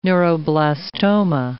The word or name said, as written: neuroblastoma